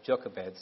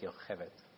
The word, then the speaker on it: Yocheved: I have a hard time with Jochebed, so I go with Yocheved, which is the Hebrew pronunciation.